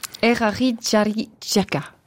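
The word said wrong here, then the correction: Eraritjaritjaka: Quelque chose comme ça? Vous voulez me le dire à ma place? – Erarija Rijaka. – C'est mieux.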